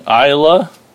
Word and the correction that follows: Ayla: Ayla, A Y L A.